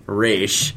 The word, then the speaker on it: Ra's: Personally, I love Bruce's rematch with the Mutant Gang, the leader in the Dark Knight Returns, Batman versus Ra's al Ghul, uh, pronounced with the S H, Raish.